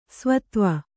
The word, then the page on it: ಸ್ವತ್ವ: svatva